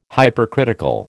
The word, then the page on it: Hypercritical: American English